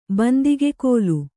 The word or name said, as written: ಬಂದಿಗೆಕೋಲು